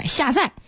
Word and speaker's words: 下载: 当然昨天也有一些专家对于一些汉字的统读发音提出了异议啊，有一位专家就对记者直言，比方说这个下载下载念四声载，表达的是搬运的意思，现在被改成了三声载，就失去了原来有的特殊含义。